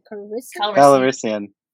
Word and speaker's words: Calrissian: Calrissian.